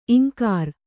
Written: ಇಂಕರ

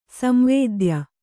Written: ಸಂವೇದ್ಯ

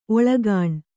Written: ಒಳಗಾಣ್

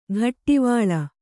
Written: ಘಟ್ಟಿವಳ

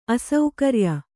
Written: ಅಸೌಕರ್ಯ

